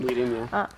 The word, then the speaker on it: Whedon: Yeah, I think in a sense, I think it's Joss Whedon says, or Whedon, I don't know how to say this last name. – Whedon, yeah.